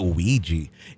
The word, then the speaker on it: Ouija: Another cautionary tale about Ouija boards, I hear. – I like how Robert said Ouija. That was, and I actually, I had to spell it Weegee. So almost like the Bee Gees, but Ouija.